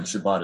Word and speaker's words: Shabbat: Shabbat is how you say it in Hebrew.